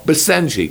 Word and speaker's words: Basenji: How about the, I guess it's pronounced, is it the Basenji? B-A-S-E-N-J-I? Or, no, Basenji.